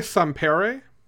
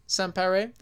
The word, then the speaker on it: Sampere: penciler Daniel Sampara. Is that how you pronounce his name? – I usually say Sampere. – Sampere. That sounds better.